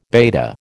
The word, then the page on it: beta: American English